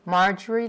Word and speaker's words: Marjorie: Marjorie Louise Kuhn, M-A-R-J-O-R-I-E, L-O-U-I-S-E, K-O-O-N-E.